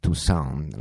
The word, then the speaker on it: Toussaint: la prima di due dedicata al genio di Alain Toussaint, anzi Toussaint, la pronuncia appunto alla francese,